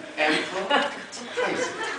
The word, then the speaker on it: empathize: 그런데 발음을 하니까 우리 둘째 딸이 제발 이렇게 발음하지 말라고 해서 의식이 되는데 엠퍼타이즈, 엠퍼타이즈. EMPHIT입니다. Empathize.